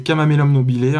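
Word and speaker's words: Chamaemelum nobile: Normalement, celui qu'on doit retenir, c'est Camamelum nobile, hein, qui s'écrit Chamae euh, melum nobile.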